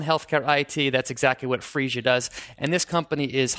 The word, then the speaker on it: Phreesia: This is Phreesia.